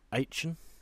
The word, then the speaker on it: Aachen: A place called Aachen, which is near to Belgium. – I think you'll find it's pronounced Aachen. – now there's a double A in there.